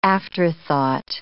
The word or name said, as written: afterthought